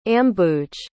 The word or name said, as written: ambuj